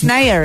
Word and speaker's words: Naya: News claims that David Spade is dating former Glee star Nivea Rivière. Did I say her name right? – I think it's Nivea. – Nivea. It's Naya, isn't it? – Naya. No, Naya. – N-A-Y-A.